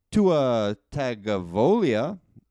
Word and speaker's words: Tua Tagovailoa: In more draft news, Tua Tagovailoa, I think I said that right. – Nailed it.